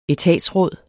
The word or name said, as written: etatsråd